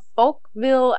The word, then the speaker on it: Folkville: The Metal Man of Folkville, Alabama. Tricky, tricky name there. You gotta be careful how you say it. That's Folkville.